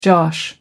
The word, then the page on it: josh: American English